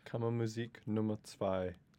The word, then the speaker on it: Kammermusik Nummer 2: Wait, so how do you really say, pronounce the name correctly? – Kammermusik Nummer 2.